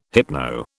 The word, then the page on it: hypno: British English